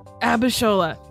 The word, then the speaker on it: Abishola: She co-created and stars in the CBS sitcom Bob Hart's Abishola. I think I said it right. – Yeah. – Abishola. – Just read the letters. – Abishola.